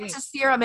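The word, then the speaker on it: Veritaserum: She was given that Veritas serum. What is it? Is that how you say it? – Oh, I think you were right. – Veritas serum, and it wasn't her fault.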